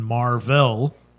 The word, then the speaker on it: Mar-Vell: And he was Captain Mar-Vell, and so we pronounced it Marvel.